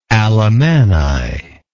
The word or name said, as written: alemanni